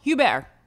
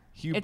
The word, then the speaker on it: Hebert: Iconetti, is it Ashley Hebert? – Nope. Hubert. – Hubert. – It's French.